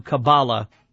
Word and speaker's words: Kabbalah: Now, coming up in about 25 minutes, we'll turn our attention to Kabbalah. That is the correct way to pronounce it.